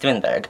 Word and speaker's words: Thunberg: Okay, so I think the movement originally took flight after Greta Thunberg, I think her name is pronounced Thunberg, started protesting not really a protest, but sitting down in front of the Swedish climate or Swedish parliament mm-hmm.